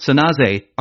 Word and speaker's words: San Jose: Coming more directly to the point, she declared, San Francisco and Oakland, Santa Clara, San Jose, which is pronounced Sanaze, are large, influential cities.